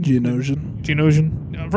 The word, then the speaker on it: Geonosian: Geonosian.